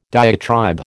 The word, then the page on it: diatribe: American English